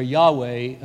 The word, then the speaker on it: Yahweh: Yahweh, Y-A-H-W-E-H, sometimes pronounced Yahweh, and that he should use that name in his translation.